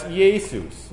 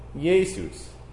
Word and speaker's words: Iesous: Now in Greek, that's Iesus. You spell that I-E-S-O-U-S. Iesus. Maybe it sounds more similar to the Spanish Jesus than it does to our English Jesus.